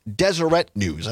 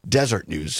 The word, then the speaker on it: Deseret News: The correct pronunciation is Deseret News. I think I was pronouncing it Desert News.